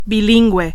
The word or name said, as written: bilingüe